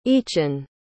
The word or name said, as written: eachan